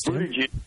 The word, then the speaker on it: Buttigieg: Yes, Buttigieg. – Nice job. I thought for sure he was going to do it. – Oh, man. – I did. I thought for... So, Buttigieg, Kamala Harris, your girl.